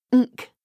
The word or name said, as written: nk